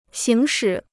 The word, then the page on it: 行使: xíng shǐ